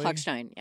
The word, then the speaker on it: Hochstein: The major work is Samuel Gardner's Piano Quintet, composed after the death of violinist David Hochstein, am I saying that correctly? – Hochstein, yes.